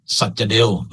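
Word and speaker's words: สัจเดว: สัจเดลคุณวารินเนี่ยออกเสียงนามสกุลแกออกเสียงว่าสัจเดลนะผมเป็นเด็กอินเดียเก่าก็จะออกเสียงว่าสักเดฟ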